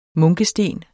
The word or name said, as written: munkesten